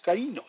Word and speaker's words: kainos: The Greek word for new in Mark chapter 16, verse 17, is kainos, pronounced kainos, meaning freshness.